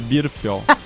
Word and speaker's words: Birfield: posto Bernfield. – E, é, é eu, eu tô falando errado, é Birfield.